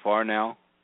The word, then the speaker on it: Farnow: Am I pronouncing your last name right, Farnow? – It's actually Farnow, but close. I get it all the Farnow? – Time. – Okay. F-A-R-N-O-W.